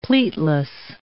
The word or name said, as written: pleatless